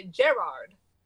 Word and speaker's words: Gerard: If you watch Graham Norton, whenever they talk about Gerard Butler, they don't say Gerard. They say Gerard. – Like a garage.